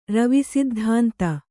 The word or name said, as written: ರವಿಸಿದ್ಧಾಂತ